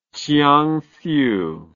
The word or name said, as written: chiangsu